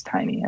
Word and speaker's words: Tainy: By the way, it's not Tainy, it's Tiny. I mispronounced his name.